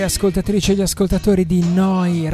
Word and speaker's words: Neu: Oh, Noi Radio Neu Radio si scrive Neu. Si pronuncia Noi e vuol dire nuova, nuova emittente urbana da Bologna.